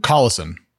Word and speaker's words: Collison: Collison, I believe.